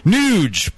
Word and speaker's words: Nuge: And this is from Nuge, pronounced like huge.